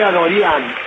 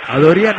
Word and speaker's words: Adorian: Yo dije Adorian y me parece que se pronuncia así. – ¿Y por qué es de ascendencia armenia el amigo? – Bueno, Adorian entonces será.